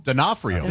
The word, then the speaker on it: D'Onofrio: Born in 1959, Vincent D'Onofrio? – D'Onofrio. – D'Onofrio? – Yeah.